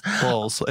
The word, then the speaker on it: Bowles: Bowles. It's Bowles. – Bowles. Bowles. Okay. Just Camilla. – Bowles. Are you kidding? – Bowles. Camilla Parker Bowles. I think it's actually pronounced Bowles in the UK.